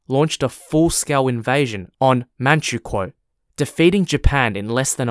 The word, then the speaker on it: Manchukuo: The Japanese had controlled the northeast of China from 1931 and established a puppet state called Manzhouguo, or pronounced in English as Manchukuo, Manchukuo spelt M-A-N-C-H-U-K-U-O.